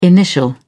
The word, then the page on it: initial: British English